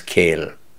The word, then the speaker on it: coill: Well, he said then, C-O-I-L-L, which means a wood, is pronounced the same as kill.